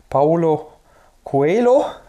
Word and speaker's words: Paulo Coelho: Das ist von Paulo Coelho.